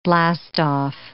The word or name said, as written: blastoff